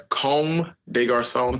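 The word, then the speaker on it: Comme des Garçons: um, a nice Como de Garcon. Uh, is that how you pronounce that? Um, no, it's Comme de Garcon. It's a French there we go.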